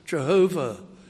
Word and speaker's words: Jehovah: We pronounce it Jehovah, the self-existent God who is the source of all creation, all power and all life.